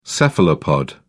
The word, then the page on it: cephalopod: British English